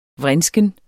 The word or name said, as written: vrinsken